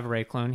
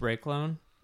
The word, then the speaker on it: Rei Clone: I also am glad you guys said your name, because even though I was confident it's Rei Clone, it's spelled R-E-I, and then clone is spelled clone. I was worried. – Can you spell it anyway? – People really struggle with that name, especially when we were on tour. We got REI clone, Rei Clone, just anything but how it's pronounced.